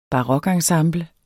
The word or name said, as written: barokensemble